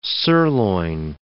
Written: sirloin

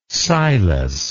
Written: Silas

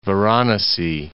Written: Varanasi

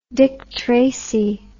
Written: Dick Tracy

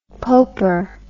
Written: poper

